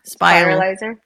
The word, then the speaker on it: spiralizer: Because I'm still looking for a really good zoodle, you know, or spiraler. I always say it wrong. – Spiralizer. – Thank you. – Spiralizer. Yes. Yeah, I know.